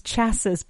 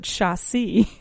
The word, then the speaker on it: chassis: What a sunroof is, we talked about the chassis, how it's not pronounced chassis, but chassis.